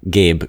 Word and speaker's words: Gabe: Gabe, G-A-B-E, because they always, if I say Gabriel, they never spell it right. And also if I say Gabe, they're like, G-A-Y? I'm like, no. G-A-B-E.